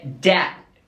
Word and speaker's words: debt: Até nem mal se pronuncia o T, é debt, né? Então, até pode falar debt, mas se você procurar pela pronúncia aí, vai ver que o T quase não é pronunciado.